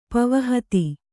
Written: ಪವನಹತಿ

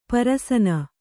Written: ಪರಸನ

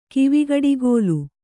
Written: ಕಿವಿಗಡಿಗೋಲು

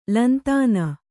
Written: ಲಂತಾನ

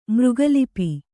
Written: ಮೃಗಲಿಪಿ

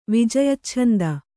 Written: ವಿಜಯಚ್ಛಂದ

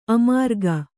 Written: ಅಮರಿಕೆ

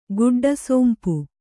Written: ಗುಡ್ಡಸೋಂಪು

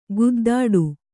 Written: ಗುದ್ದಾಟ

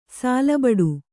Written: ಸಾಲಬಡು

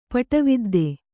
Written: ಪಟವಿದ್ದೆ

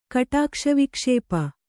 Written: ಕಟಾಕ್ಷವಿಕ್ಷೇಪ

